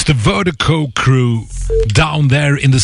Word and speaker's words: Virdiko: And Vertigo, Vertigo is spelled V-I-R-D-I-K-O. That's V-I-R-D-I-K-O, pronounced Vertigo.